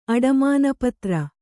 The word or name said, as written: ಅಡಮಾನಪತ್ರ